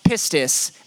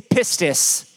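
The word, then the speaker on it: pistis (πίστις): It's a word, and it's pronounced pistis. Everybody say pistis.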